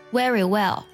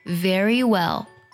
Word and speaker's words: very well: Very well，这是第一种错误。还有 very well，这是第二种错误。第三种错误呢，就是 very well。正确读音呢是 very well， very well， very well。